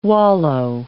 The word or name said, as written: wallow